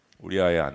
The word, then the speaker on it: 우리아: 우리 아내가 우리아의 아내가 우리의 아내가 아니라 우리아의 아내.